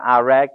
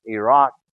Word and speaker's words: Iraq: They don't call it Iraq and Iran, as our news commentators do. It's Iraq and Iran. They pronounce the I as an E.